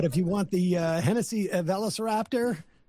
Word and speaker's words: Velociraptor: Velociraptor, which is a, thank you, which is a souped-up Ford Bronco, or $100,000 in cash.